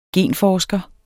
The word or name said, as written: genforsker